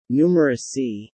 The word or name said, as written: numerousy